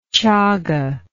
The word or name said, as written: chaga